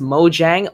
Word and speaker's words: Mojang: Why do you say it's Mojang? – Okay, because the creators of Minecraft, they are Swedish. Right? – Okay, that makes sense. – Yeah, they're Swedish. So the J could be pronounced like Y.